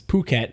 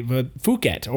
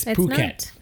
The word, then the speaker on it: Phuket: yes, that's actually how it's pronounced. It's Phuket. A lot of people, at least Americans, and actually a lot of English speakers would say Phuket or fuck it. – Right. – Because it's a PH. The PH for us has the F noise, but it's not. It's Phuket.